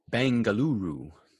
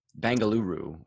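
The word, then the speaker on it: Bengaluru: Bangaluru. – Huh? – Bangaluru is Bangalore, just how they say it locally.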